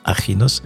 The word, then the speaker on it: Axinos: On peut également déguster la succulente cuisine grecque de Yanis au restaurant Achinos, ça s'écrit Axinos, mais on prononce Achinos, donc euh, toujours à Naoussa.